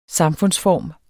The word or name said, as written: samfundsform